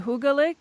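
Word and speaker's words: hyggelig: Cheap restaurant is 30,600 hits, but actually you're going to have to pronounce it hygelig.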